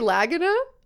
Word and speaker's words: Lagina: It could be Lagina, but it's spelled like Legina. and his partner Craig Tester. like testing. Are you kidding? – I'm not. – Legina and, and Tester.